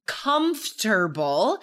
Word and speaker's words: comfortable: We don't say it how we spell it. It is spelled comfortable. So if you are, are only hearing it, you might spell that wrong. You're going to miss that middle O.